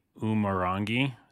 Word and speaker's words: Umurangi: Well, just to finish up on the Umurangi.